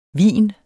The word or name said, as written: VINENES